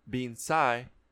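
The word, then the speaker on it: bean sí: The word Banshee comes from the Irish being sigh, which is actually pronounced Banshee. So bean, like as in like frijole bean, pinto bean, and then S-I with apostrophe there. – But it's pronounced Banshee.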